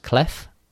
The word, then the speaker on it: clef: And the second Grimoire, is it pronounced clef in French? – Yeah.